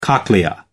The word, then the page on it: cochlea: American English